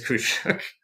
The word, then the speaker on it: Khusyuk: It's spelled K-H-U-S-Y-U-K. But I understand that the pronunciation is Kushuk.